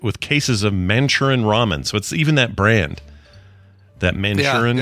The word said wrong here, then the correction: Maruchan: Yeah, Maruchan. – Maruchan, or how you say it? – Yeah.